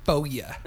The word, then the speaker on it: Foria: Yeah, and the name is Foria, F-O-R-I-A. I assume many people will be interested in this. – But it's pronounced Foia. – Foia. I like it.